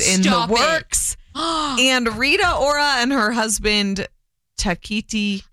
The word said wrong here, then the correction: Taika Waititi: I love him. – No, sorry. Taika Waititi. – Mm-hmm. – Taika Waititi. Sure.